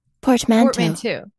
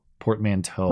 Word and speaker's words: portmanteau: It's not according to this Google voice person. – Portmanteau. – They say toe. Portmanteau. – Portmanteau. – Portmanteau.